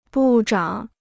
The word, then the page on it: 部长: bù zhǎng